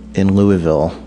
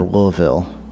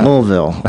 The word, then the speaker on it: Louisville: He, Chad lives in Louisville, or Louisville, I'm supposed to say. Damn. – They want less syllables on that. – Louisville.